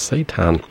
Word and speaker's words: Seudan: No, not Beelzebub, but a Scottish group, and the name is spelt S-E-U-D-A-N, and the note that comes with it insists that it's pronounced Satan, S-E-U-D-A-N, and that's available on Green Tracks Records.